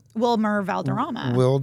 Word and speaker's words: Wilmer Valderrama: Wilmer Valderrama. Say that 10 times fast. – Wilmer Valderrama. – What did I say the first time? – No, you said it right.